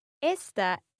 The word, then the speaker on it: esta: Yeah, just a bit more attention with the pronunciation of esta, okay? Really open that e sound.